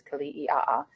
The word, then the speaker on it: Kili'i'a: There's four vowels in a row, which is quite surprising to most people. There should be sort of the okinas, the glottal stops, but that was a long time ago that got removed from our last name. But yes, that's how you pronounce it, is Kili'i'a.